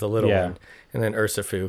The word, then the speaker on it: Urshifu: Urshifu.